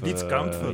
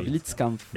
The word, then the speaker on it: Blitzkampf: Oui, bon, Akatsuki Blindkampf. – Blitzkampf. – Blitzkampf.